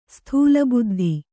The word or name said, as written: ಸ್ಥೂಲಬುದ್ಧಿ